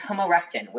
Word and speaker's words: homorechten: And it's homorectin, which if I... – It's what? – H-O-M-O-R-E-C-H-T-E-N. Homorectin.